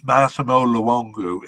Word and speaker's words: Massimo Luongo: Sorry, did I say Luongo? His name is Massimo Luongo, if I said it wrong just now.